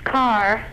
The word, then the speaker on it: Karr: It's Carr. – K-A-R-R. – Like an automobile? – Yeah. – Huh? – Yeah. – Hello, operator? – Sir, it isn't exactly like an automobile. No, it's K-A-R-R.